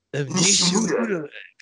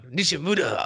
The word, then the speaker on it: Nishimura: Let, us let's let's let's get this correctly. It's on the Nishimura. – Nishimura. Come on, Nishimura.